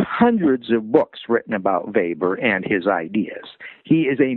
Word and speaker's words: Weber: It's spelled like Weber, W-E-B-E-R, but it's pronounced Weber. He was German.